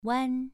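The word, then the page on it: wan: tone 2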